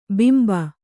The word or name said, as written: ಭಂಭಾ